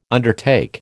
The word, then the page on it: undertake: American English